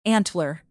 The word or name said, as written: antler